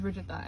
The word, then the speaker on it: Rujuta: So, my name is Rujuta, and it's literally spelled R-U-J-U-T-A.